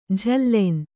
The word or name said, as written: ಜಾಲಿನಿ